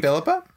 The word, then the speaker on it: Philippa: And I didn't see that show, but but in regards to Philippa Soo, uh, is it Philippa Philippa? How do you pronounce her name? Philippa. Philippa. – Philippa.